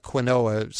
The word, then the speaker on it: quinoa: It looks like quinoa spelled out, but it's pronounced quinoa.